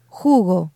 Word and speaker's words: jugo: Now, as you see in today's PDF, this word is spelled J-U-G-O. Jugo. Listen to the way that the letter J kind of sounds like an H in English. Jugo.